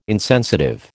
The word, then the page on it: insensitive: American English